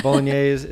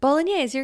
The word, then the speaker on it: Bolognese: Am I saying it right? Now you're in my head. – Bolognese, you're good.